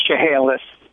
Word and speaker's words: Chehalis: Correct me if I'm wrong on this pronunciation, Chalice, Washington, listen on Sirius XM 130. – Yeah, it's Chehalis.